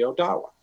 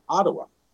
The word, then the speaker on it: Odawa: Another example of a tribe that would have been right here in beautiful Ohio is the Odawa. That is how they pronounce their name. But the French mispronounced it and misspelled it, and they said Ottawa.